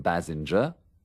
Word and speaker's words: Basinger: Por ejemplo, he aquí tres formas diferentes de pronunciar la G. – Kim Basinger, Richard Gere, Jessica Lange.